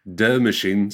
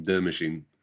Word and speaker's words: De Machine: De Machine. So it's spelled D-E space machine, De Machine.